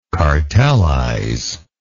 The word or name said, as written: cartelizer